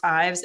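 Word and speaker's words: Yves: And one of my favorite lunch meat brands is, I think it's called Ives or pronounced Ives. It's Y-V-E-S.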